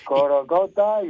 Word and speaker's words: Corocota: La afición que anime como nunca, que aparezca otra vez el espíritu de, si lo digo mal me corriges, Corocota. – Sí, no, bien dicho. – Corocota,